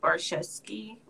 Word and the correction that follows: Boretsky: whatever. And the reason why I liked him. – Oh, Boretsky. – Boretsky, thank you.